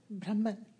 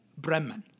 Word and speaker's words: Brahman: Brahman. – Brahman.